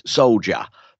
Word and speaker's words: Soulja: For anybody who's wondering why I queried that, it's because it's spelled Soulja, which does pronounce soldier, but it's not.